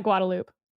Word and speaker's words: Guadalupe: We pronounce that Guadalupe. – Oh, okay. Sounds like in Kentucky, we have a town that's spelled A-T-H-E-N-S.